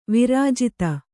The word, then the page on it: ವಿರಾಜಿತೆ: virājite; Kannada